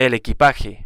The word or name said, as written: el equipaje